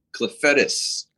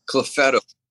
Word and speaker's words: Clufetos: The actual pronunciation is Clefetis. – Clefetis. Yeah. Clefetis. Okay. – Clefetis. It's like weird. I kept calling him Clefetto.